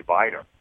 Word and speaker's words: Wiener: but it's whiner, but it's really viner. Look at it the way it's supposed to be pronounced.